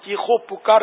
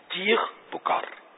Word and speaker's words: چیخ پکار: چیخو پکار بھی اہل لغت کے نزدیک ہے کہ واؤ یہاں آئے گا نہیں, بلکہ چیخ پکار دونوں کے ایک ہی معنی ہے نا, چیخ پکار یہ لفظ تلفظ درست ہوگا.